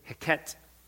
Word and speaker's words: Heket: One of the gods of Egypt was Heket, that's the way you pronounce it, represented in the form of a frog.